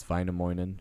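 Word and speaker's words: Väinämöinen: But it's Vainamoinen.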